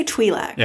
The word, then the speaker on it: Twi'lek: They pronounce it both ways in this episode. – Yeah, there's two different pronunciations. I'm going to say Twi'lek. – Yeah, that's what it's normally called.